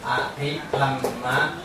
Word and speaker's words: a thi tham ma: Và cái thứ ba là a khí tham ma vì tất cả gọi là tạng vi diệu pháp. A thi tham ma từ này đọc là a thi tham ma.